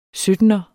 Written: syttener

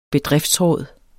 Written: bedriftsråd

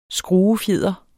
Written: skruefjeder

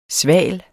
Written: sval

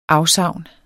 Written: AFSAVNENES

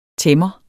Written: tæmmer

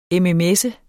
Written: mms

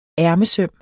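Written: ærmesøm

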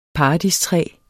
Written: paradistræ

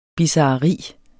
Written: bizarreri

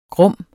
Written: grum

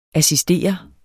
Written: assistere

